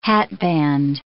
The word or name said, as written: hatband